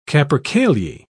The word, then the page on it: capercaillie: British English